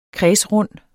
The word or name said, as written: kredsrund